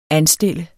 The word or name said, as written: anstille